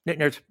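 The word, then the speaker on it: Newtownards: Newt Nards. Did I say it right? – Yeah, exactly. We're so...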